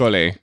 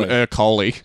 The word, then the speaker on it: Ercole: So we really butchered a lot of the Italian names in that original episode. One of them specifically was Ercole. We called him Ercole. With our terrible Australian accents.